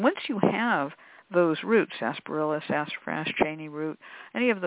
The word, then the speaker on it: sarsaparilla: There's another root that is called sarsaparilla, spelled sarsaparilla, but it really is pronounced sarsaparilla root.